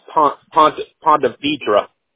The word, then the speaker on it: Ponte Vedra: Yeah, it's actually pronounced Ponte Vedra. It's the way we pronounce it down here anyway.